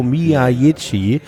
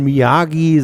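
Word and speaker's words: Miyaichi: Der Mann heißt Rio Miyajichi und wird gefälligst auch so ausgesprochen. Ich kann dieses Miyachi Magalitschki oder wie auch immer, der kommt weder aus Polen, noch dürft ihr das irgendwie abkürzen und Miyagi sagen, das war der Mann, der dem Karate-Kid den Karate beigebracht hat.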